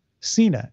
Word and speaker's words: Cena: Cena. It rhymes with peacemaker.